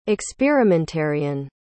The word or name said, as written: experimentarian